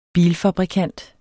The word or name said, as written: bilfabrikant